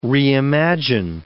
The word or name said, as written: reimagine